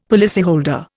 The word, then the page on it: policyholder: Indian